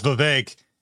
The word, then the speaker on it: Vivek: they're going to have to learn how to say his name correctly. It's Vivek.